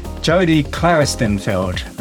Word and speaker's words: Jodi Klaristenfeld: My guest today, Jodi Klaristenfeld.